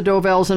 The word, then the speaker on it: Dovells: The Del Vos. – Number six. – Del Vos. I'm yeah, not saying it right. – That's all right. You know it's the Dovells, and I'll give it to you for that.